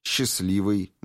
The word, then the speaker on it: счастливый: и вообще не обозначать никакого звука, «счастливый», «местный». Звук «Т» не произносится.